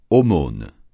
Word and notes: aumône: francais

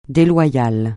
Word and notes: déloyal: français

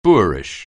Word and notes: boorish: anglais

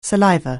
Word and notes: saliva: anglais

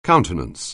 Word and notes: countenance: anglais

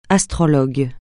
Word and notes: astrologue: francais

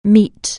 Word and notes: meet: anglais

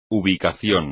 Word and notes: ubicación: espagnol

